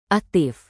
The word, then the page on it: aktif: indonesian